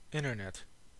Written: internet

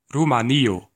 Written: Romania

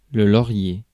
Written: laurel

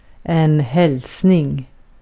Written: greeting

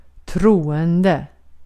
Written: troende

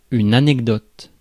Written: anecdote

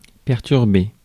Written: disrupt

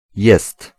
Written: faire

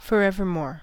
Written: forevermore

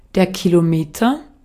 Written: kilometre

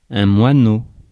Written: moineau

